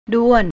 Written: ด้วน

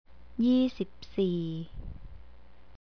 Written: yeesipsee

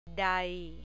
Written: dai

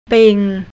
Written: ping

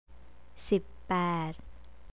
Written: sippad

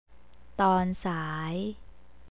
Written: tonsai

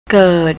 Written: kert

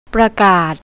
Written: prakat

